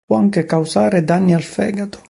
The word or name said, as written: danni